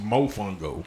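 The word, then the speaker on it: mofongo: She was telling me about some shit called Mufungo. – Mufungo. – Mufungo last night. And I was like, what the fuck is that? – What is that? – And she was like, it's pronounced Mofungo.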